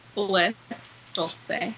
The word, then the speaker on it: Bliss Dulce: Bliss Dulce. – Spell it. – B-L-I-S-S-D-U-L-C-E.